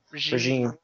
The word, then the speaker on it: regime: And then all of a sudden, he can't do anything, and he's talking about, well, the new resume wants I think I mispronounced that word. – Regime. – Yeah, the new regime wants their guys.